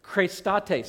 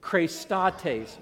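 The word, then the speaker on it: Christates (χρηστότης): Here's how you say it. Christates. Say that with me. Christates. One more time. Christates.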